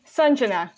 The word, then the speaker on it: Sanjana: It's Sanjana, like S-U-N, like think about the sun.